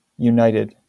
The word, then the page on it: united: American English; u•nit•ed